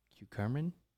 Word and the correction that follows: curcumin: Curcumin, lemon balm, and zinc.